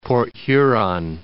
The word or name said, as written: Port Huron